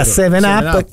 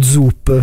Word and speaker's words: 7-Up: Però ti posso dire che uno sponsor della Fiorentina, che se lo ricorderanno tutti, e fu una bibita che all'inizio non sapevamo come si pronunciasse, che era 7-Up, che e noi da ragazzi la prime volte, scusi mi dà uno Zup, perché sembrava con la Z, no? E invece era un 7, sì.